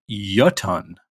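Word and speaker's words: Jötunn: I thought there was also, by the way, I did look up how to pronounce Jotun, as we were saying it. Apparently it's a Icelandic word. And it's actually apparently pronounced Jotun.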